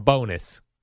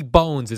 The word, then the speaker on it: Bones: Although I heard Harris Allen say this, and I think everyone's pronouncing the guy's name wrong. Bullpen pitching coach for the Mets, Ricky Bones, has been let go. Everyone's calling him Bonus. Wouldn't you go, if you were a baseball guy, Ricky Bones is a great name.